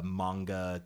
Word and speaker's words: manga: I mean, but maybe since you're like you know you're you're so into uh, manga.